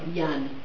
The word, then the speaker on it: Yan: On his website, it's spelled Y-A-N. – Yes, yeah. That's how it's pronounced. – Yeah. – Jan.